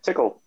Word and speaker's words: TQL: The TQL Stadium, that's pronounced tickle, right? – Yep, that's right.